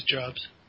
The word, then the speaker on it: Jobs: Am I even saying his name right? Is it really Jobs? – I think it is Jobs. – Oh, yeah, I'm pretty sure it's Jobs.